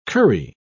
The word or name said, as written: curry